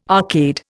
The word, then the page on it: arcade: Indian